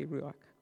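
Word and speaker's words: ruach (רוח): I'm not sure if people reading Hebrew are not as familiar with the Scottish loch type noise pronounce it like that, but I always say ruach.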